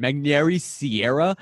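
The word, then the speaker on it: Magneuris Sierra: Magnary Sierra. And it took me so long to figure out how to pronounce Magnary Sierra, because you almost say it with like a W instead of an R.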